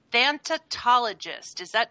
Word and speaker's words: thanatologist: Is an interfaith minister, clinical chaplain, and certified thanatologist.